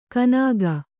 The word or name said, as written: ಕನಾಗ